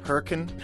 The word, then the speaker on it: Hurricane: About 20 minutes up the road from Charleston, there's a town called, it's spelled Hurricane, but it's pronounced Hurricane.